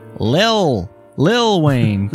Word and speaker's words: Lil Wayne: So today, Lil Wayne turns 38. I said that wrong. I'm sorry. I said little. – Yeah. – Lil Wayne turns. I still. It sounds like Lil Wayne turns 38.